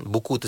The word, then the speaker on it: Bukoo: like dan uh, platform yang uh, buku tersebut namakan Simply Call Buku tapi ejaannya B U K O O.